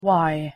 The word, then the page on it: y: English